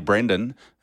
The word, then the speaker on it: Brendan: But he's definitely Brendan, and well done to him. – Rhymes with? – What does it, John Sendon? – John Sendon. Sendon. – Brendan rhymes with Sendon.